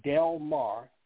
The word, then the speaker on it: Delmar: .com, and you put /, and my name, and it's spelled Delmar, D-E-L-M-A-R, America, and that's with two A's, that's A-A-M-A-R-I-C-A.